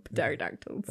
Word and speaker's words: pterodactyls: That's how I call them, pterodactyls. – Okay. – The P is silent, if you didn't know.